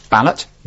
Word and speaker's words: Ballett: At Mark Ballot. Now, my name is spelled B-A-L-L-E-T-T. So at Mark Ballot's my Twitter.